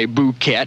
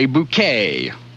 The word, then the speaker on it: bouquet: It's spelled P-O-T-P-O-U-R-R-I, but you don't say the T. It's not potpourri. It's potpourri. Bouquet. You don't say bouquet, you say bouquet.